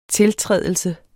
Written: tiltrædelse

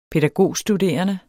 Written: pædagogstuderende

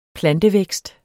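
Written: PLANTEVÆKST

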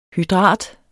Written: hydrat

